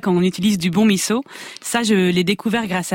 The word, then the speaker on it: miso: Alors je dis miso et pas miso, car c'est comme ça qu'on le prononce au Japon.